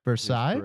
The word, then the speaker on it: Versailles: Did I pronounce that right? I know you're a world traveler. – Versailles. Correct.